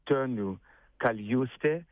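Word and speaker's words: Tõnu Kaljuste: Τη Δευτέρα, 15 Ιουνίου, θα έρθουν οι πιο ελίτ δυνάμεις, μουσικές δυνάμεις από την Εσθονία, υπό τη διεύθυνση του περίφημου μαέστρου Τόνιου Καλιούστε. Δεν είμαι σίγουρος ότι έτσι προφέρεται, αλλά πάντως είστε πολύ κοντά.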